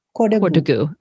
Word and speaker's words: Kodagu: How do I pronounce the name of the land? – Kodagu. – Okay. So Kodagu.